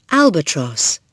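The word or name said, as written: albatross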